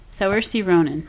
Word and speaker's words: Saoirse Ronan: Saoirse Ronan? She's new. – Saoirse Ronan.